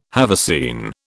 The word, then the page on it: haversine: British English